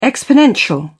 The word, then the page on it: exponential: British English